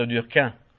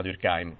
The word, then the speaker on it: Durkheim: Allora, siccome lui si sentiva molto francese, lui pretendeva di essere chiamato Durkheim. Come sarebbe la pronuncia francese, solo che se uno dice Emile Durkheim non te capisce nessuno perché il resto del mondo ha deciso che si chiama Durkheim,